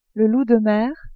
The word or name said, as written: loup de mer